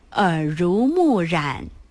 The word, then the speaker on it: 耳濡目染: 这个字可别读错，读成了乳，说成了耳濡目染。正确读音是耳濡目染。